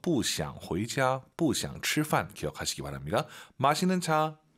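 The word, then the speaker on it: 茶: 이게 분명한 건 한글에 있는 그 차, 한국어의 차는 아니에요. 발음이 차 이거 아니에요. 약간 차 끈덕지게 차, 그죠? – 是的. – 너무 또 우리가 오버해서 촤 이건 아니에요. 차.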